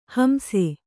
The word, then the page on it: ಹಿಂಸೆ: kannada